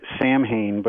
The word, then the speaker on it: Samhain: But you know, if you go back to its earliest roots, it's associated with a Celtic festival called Samhain, which, you know, most Americans would look at that word on a piece of paper and think it is pronounced Samhain, but it's pronounced Samhain, and essentially it means the end of summer.